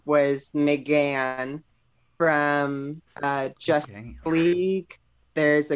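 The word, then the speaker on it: M'gann: And her her fake personification's name is Megan, and based off of this whole sitcom from the 80s, but she pronounces it McGann.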